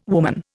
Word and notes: woman: Indian

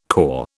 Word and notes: CORE: British English

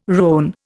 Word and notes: rone: Indian

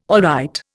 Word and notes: alright: Indian

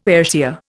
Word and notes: Ayrshire: Indian

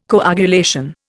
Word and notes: coagulation: Indian

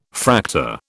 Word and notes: Fraktur: British English

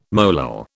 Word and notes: molal: British English

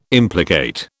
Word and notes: implicate: British English

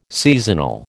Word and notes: seasonal: American English

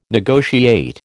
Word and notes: negotiate: American English

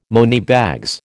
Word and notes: moneybags: American English

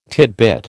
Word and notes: tidbit: American English

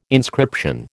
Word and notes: inscription: American English

